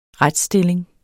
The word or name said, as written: retsstilling